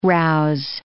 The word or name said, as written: rouse